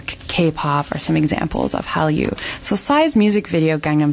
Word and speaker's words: Hallyu: So something called the Korean Wave, which is Hallyu. Am I pronouncing it right? – Yeah.